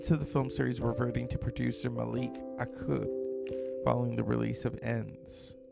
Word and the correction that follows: Akkad: What is his name? Let me say it properly. Malek Akkad.